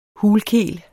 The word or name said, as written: hulkel